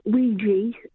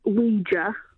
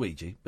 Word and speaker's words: Ouija: First off, it's not Ouija, it's Ouija. – Well, it's pronounced Ouija, but yes, next point.